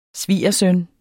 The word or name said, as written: svigersøn